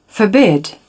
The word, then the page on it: forbid: British English